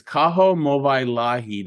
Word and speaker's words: Kaho Movailahi: Your full first name, I believe, is Kaho Movailahi. Did I say that right? – Hey, you said that really well. – Ooh, what? Yeah. – Not a lot of people get that on the first try.